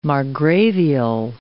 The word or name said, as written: margravial